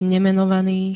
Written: nemenovaný

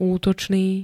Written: útočný

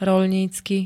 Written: roľnícky

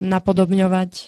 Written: napodobňovať

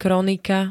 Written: kronika

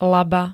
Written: laba

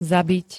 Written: zabit

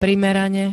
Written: primerane

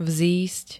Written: vzísť

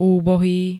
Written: úbohý

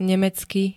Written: nemecky

